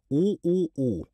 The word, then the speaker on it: OOO: In Korean, the name is simply pronounced OOO.